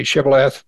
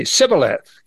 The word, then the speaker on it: Shibboleth: If he said no, then they would say, then say Shibboleth, and he would say Sibboleth, because they couldn't pronounce that word.